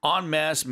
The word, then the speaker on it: en masse: And when you're pronouncing it, it's spelled E-N-M-A-S-S-E, two words, but it's pronounced en masse, yeah. – Not en masse.